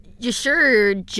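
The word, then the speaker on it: Y'Shaarj: And I researched how to say... Yasharaj, because look how that's spelt. – Yasharaj. – Yeah, it's a little stupid, but that's how it's pronounced, I promise.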